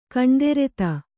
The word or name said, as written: ಕಣ್ದೆರೆತ